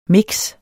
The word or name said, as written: mix